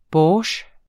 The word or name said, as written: borsjtj